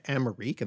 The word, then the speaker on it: Amerike: So according to this claim, Richard Amarik, and that's spelled Amerike,